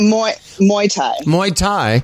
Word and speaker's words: Muay Thai: Is that how you pronounce it? Muay Thai? – Muay Thai fighter. Muay Thai.